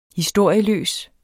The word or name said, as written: historieløs